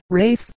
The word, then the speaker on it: Ralph: The name Ralph is pronounced as Rafe, as in rate, which I believe is Old English, and the names Saunders and Sanders are pronounced exactly alike, both being called Sanders.